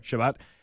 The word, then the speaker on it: Shabbat: Is that how you say it? – That's right. – Shabbat.